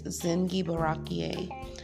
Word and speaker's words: Zingiberaceae: It belongs to the Jin Gibaraki family and is closely related to turmeric, cardamom, and galangal. Did I say that right? Zingiberaceae. I think it's Zingiberaceae.